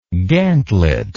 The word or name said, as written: gantlet